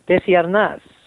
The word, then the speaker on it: Arnaz: If we were in Spanish and I was using a proper Spanish accent, it would come out more like Desiernas, right?